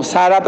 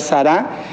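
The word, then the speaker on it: Zara: Ahí se ve como Sara, pero se debe pronunciar Sará.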